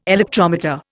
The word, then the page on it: electrometer: Indian